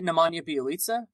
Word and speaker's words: Nemanja Bjelica: I say it Nemanja Bjelica.